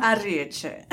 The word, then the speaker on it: Arrieche: Arrieche.